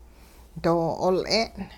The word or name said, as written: doo-'ool'e'n